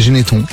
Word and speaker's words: Geneston: Nicolas habite Geneston. On est dans le département 44, Loire-Atlantique. Nicolas, j'ai dit Geneston, c'est Geneton. On prononce pas le S.